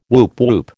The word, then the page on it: woop woop: American English